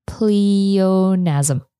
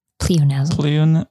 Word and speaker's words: pleonasm: It's called pleonasm. Pleonasm. – What? How do you spell it? – P-L-E-O-N-A-S-M.